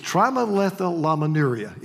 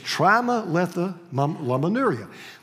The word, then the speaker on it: trimethylaminuria: It's trimethylaminuria. It took me an hour to pronounce that yesterday. It's trimethylaminuria.